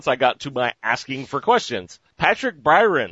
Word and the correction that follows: Byrne: Did I say that right? Or Byrne? Byrne, yeah, sorry.